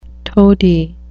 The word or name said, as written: toady